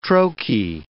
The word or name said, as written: trochee